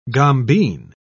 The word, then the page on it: gombeen: British English